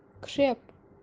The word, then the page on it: Khreap: kashmiri